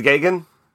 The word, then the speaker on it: Geoghegan: Geoghegan? – Ted Geoghegan.